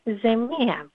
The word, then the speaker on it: ζεμία: Τη ζημιά τη λέμε ζεμία.